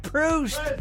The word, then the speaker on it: Proust: Proust. – Proust.